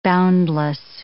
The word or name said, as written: boundless